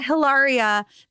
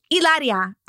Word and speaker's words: Hilaria: But one of the clips you posted, the one that really got me good, was she was like, it's not Hilaria. The H is silent. It's Ilaria.